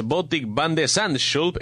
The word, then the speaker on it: Botik van de Zandschulp: Botik van de Zandschulp,